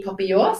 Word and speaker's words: papillon: Kan ikke vi den korrekte uttale? Hvordan sier vi det? – Papillon. Ja. Yes. Med den nasale lyden ja. På slutten.